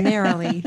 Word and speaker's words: Neroli: How do we pronounce Neroli? – Neroli. – Okay, you guys heard an expert. – Narrowly. – Okay, don't take my word for it. Narrowly. That is how I will say it from this point forward exclusively.